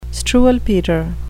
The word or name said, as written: struwwelpeter